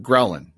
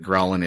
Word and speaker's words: ghrelin: And so that, just like the first couple of syllables come out, it's gr, right? So your stomach is growling. That's how you know that ghrelin is reaping up.